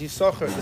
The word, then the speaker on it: יששכר: Usually, I imagine the Baal Kori here pronounces it every time he lanes it as Yששכר, the second sin is silent.